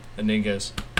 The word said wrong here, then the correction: Iniguez: Actually, I think it's pronounced Iniguez.